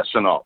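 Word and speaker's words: Laviska Shenault: Is it LaVisca or LaVisca Chenault? – Yeah, Chenault, that's it.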